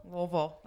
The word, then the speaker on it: Louisville: So can you, for the listeners, say it properly? – Louisville? – Louisville, that's right. – Yep. – Just wanted to make sure. – Yep, there's a sign, L-O-O-A-V-U-L-L.